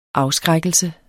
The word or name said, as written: afskrækkelse